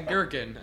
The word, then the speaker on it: gherkin: No, it's like a G H E R K I N. So it's a silent H. Gherkin. – Herkin? – No, it's a silent H, not a silent G.